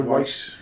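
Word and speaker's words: Wyss: Is it Amanda Weiss? It's W-Y-S-S. Amanda Weiss.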